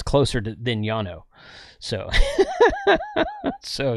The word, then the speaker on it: Llano: Incorrect. Llano starts with a double L, and it's a Spanish word, and double L in Spanish is pronounced like a Y.